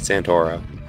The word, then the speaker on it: Santoro: Yeah, that's it, Santoro.